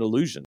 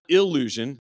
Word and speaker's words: allusion: And we see how in Matthew 21, there's a quote and an allusion. Okay? The quote, not illusion, right? Allusion. I just want to make sure I pronounce that correctly.